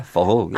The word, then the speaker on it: Forró: And the Forró was, have I said it correctly? Forró. – It's pretty good, yeah. Forró, yeah.